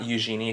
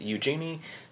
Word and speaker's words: Eugenie: Not Eugenie. If you pronounce it French, that's not correct, she'll tell you. It's Eugenie. She's named after the British princess, not any French person.